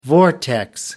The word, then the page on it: vortex: American English